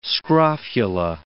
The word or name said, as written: scrofula